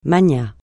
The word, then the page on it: magnat: francais